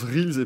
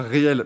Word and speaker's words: Reels: Euh, ah Anthony qui découvre qu'on prononce Reels et pas réel.